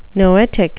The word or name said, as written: noetic